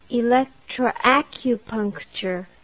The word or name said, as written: electroacupuncture